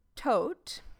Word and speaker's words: Todt: Until Anthony Tote, which is kind of funny because Tote is death in German. it's spelled differently. This is T-O-D-T, but pronounced the same.